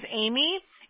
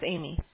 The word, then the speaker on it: Amie: Listen, my name is Amy, and it's spelled A-M-I-E, okay? And people call me Annie, and I'm quick to let them know that's Amy.